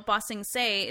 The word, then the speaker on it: Ba Sing Se: Now, Ba Sing Se is the only Earth Kingdom left.